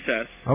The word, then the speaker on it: DeCSS: Is that how you say it? DCS? Is it a word now? – Yes, DCS. – Okay. – It's just easier than D-E-C-S-S. – Well, it was said many different ways in court. I heard people saying D-E-C-S, DCS, you know, but...